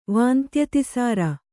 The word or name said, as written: ವಾಂತ್ಯತಿಸಾರ